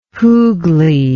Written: Hugli

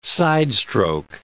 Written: sidestroke

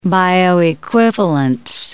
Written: bioequivalence

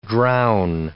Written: drown

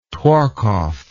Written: Tworkov

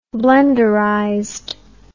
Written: blenderized

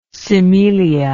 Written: cimelia

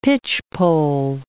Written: pitchpole

